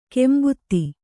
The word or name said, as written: ಕಾಂಬೋದಿ